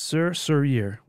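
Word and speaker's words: Sœur Sourire: Yeah, that would be pronounced Sir Sourire, which is Sister Smile in French.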